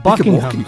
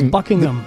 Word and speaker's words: Buckingham: Es Buckingham. – Y de hecho, el H medio que se es medio silente. Buckingham.